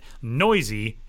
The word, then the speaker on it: Neuse: Jorge Mateo and Sheldon Noisy, which, by the way, if you've looked at Sheldon's last name and wondered, how do you say that? Noisy, N-E-U-S-E.